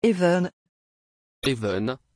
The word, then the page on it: évan: French